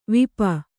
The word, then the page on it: ವಾಪಿ: vāpi